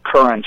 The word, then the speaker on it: Curran: The title is Connor Race, C-O-N-N-O-R, Race, : The Tontine Plot, T-O-N-T-I-N-E. And my last name is spelled Curran, C-U-R-R-A-N, Michael.